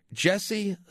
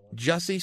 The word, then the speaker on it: Jussie: And Juicy, it's spelled weird. J-U-S-S-E, but it's pronounced like Jesse or something.